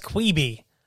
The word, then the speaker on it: Quibi: As it's pronounced, Quibi. Quick Bites is what that's short for.